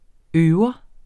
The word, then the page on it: øver: ˈøːwʌ